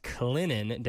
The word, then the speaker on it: Clinton: Yeah, so it's spelled Clinton, but it's pronounced Clinton down in South Carolina.